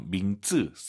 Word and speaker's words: 名字: 이름 발음하실 때요, 민즈, 쌍지읒으로 정말 많이 하시더라고요. 민즈, 이성경성, 지읒 느낌으로 민즈, 까먹었다, 잊어버렸다, 忘了, 잊지 마시기 바랍니다.